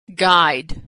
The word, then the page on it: guide: g; American English